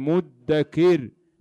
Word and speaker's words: مدكر: هذه الآية في سورة القمر، وكان يقرأها مدكر بتثقيل الدال، وقد فسرها الإمام أبو داود فقال: مضمومة الميم مو مفتوحة الدال مدكر مكسورة الكاف.